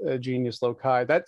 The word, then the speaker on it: genius loci: Is it genius loci or is it genus loci? – That was what I was going to say, and that's how I pronounce it. I just pronounce it genius loci.